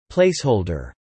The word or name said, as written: placeholder